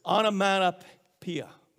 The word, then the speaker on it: onomatopoeia: It's a onomatopoeia. Onomatopoeia. Onomatopoeia. You know what that is? Did I say it right? I did? Thank you.